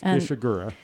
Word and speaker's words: Ishiguro: It's Ishiguro. – Thank you. – Yes. Oh, yes. Ishiguro, Katsuo.